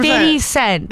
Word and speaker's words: 50 Cent: Oh, now it's 50 so Cent. – 50. – Now it matters. It matters how you say it. 100%. – 50 Cent. – There you go.